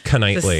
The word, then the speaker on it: Knightley: That's tough, yeah, because it's the silent K. – We never would have got that, Knightley.